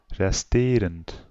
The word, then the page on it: odd: American English; /ɑd/; British English; /ɒd/